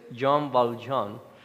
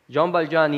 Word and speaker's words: Jean Valjean: y si saben francés, disculpen mi pronunciación, pero es Jean Valjean,